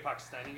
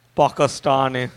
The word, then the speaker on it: Pakistani: Pakistani guy? – Don't say Pakistani that way. – Pakistani. You're supposed to say it like that.